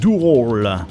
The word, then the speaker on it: Ural: On part maintenant en Italie avec le troisième album, Roll. Ça s'écrit U-R-A-L.